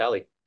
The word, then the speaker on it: Eli: Yeah, I grew up in Mexico, and the spelling for Ellie was E-L-I. And then I moved out here, and I never changed it. And so about 95% of people call me Eli, and I take no offense to it. But you asked for the correct pronunciation, so it's Ellie.